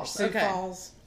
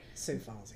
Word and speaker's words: Sioux Falls: Sioux Falls. – All right. A teaching hospital for the University of South Dakota. All right. – Sioux Falls, yeah.